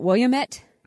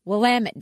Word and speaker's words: Willamette: It looks like you would pronounce it Willamette, mm-hmm. but if you go there, they will quickly tell you Willamette, damn it.